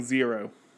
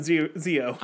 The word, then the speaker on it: Zeo: because no one can pronounce Zio correctly without saying zero. – Yeah, it's a tongue twister. – And be like, no, it's Zio to Hero, guys. There's no R. There's no R in Zio.